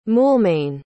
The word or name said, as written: moulmein